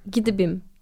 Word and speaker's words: Gdybym: Ee, Polonyaca olduğu için e, Gidibim diye okunuyormuş.